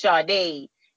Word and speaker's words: Sade: You know, well, saying it in English is Sade, but saying it in, it's actually a Yoruba, it's a Nigerian African name. It's pronounced Sade.